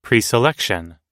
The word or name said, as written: preselection